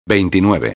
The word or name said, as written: 29